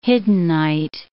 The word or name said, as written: hiddenite